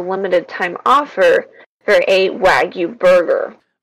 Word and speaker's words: Wagyu: This is connected to last week's discussion of, did we decide if it was Wagyu or Wagyu? – Yes. – It's Wagyu. I think it's pronounced Wagyu.